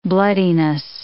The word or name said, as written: bloodiness